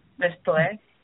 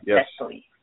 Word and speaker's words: Nestlé: I don't know if I pronounced that right. Nestlé. That's N-E-S-T-L-E. – Oh, Nestlé. – Yes, Nestlé.